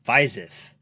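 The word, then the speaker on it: Fiziev: Viziev. Is that how you pronounce it? Viziev? – Viziev.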